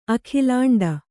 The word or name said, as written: ಅಖಿಲಾಂಡ